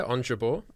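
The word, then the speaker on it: Ons Jabeur: Yeah, Onjabeur.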